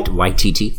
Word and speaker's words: Waititi: That's how you say it, right? Waititi? Yeah. – Waititi, yeah.